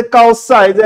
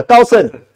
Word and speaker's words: 高盛: 等到高盛又跳出来了，哇！我老公在高赛，对不对？高高盛啊，高盛发音要正确啊。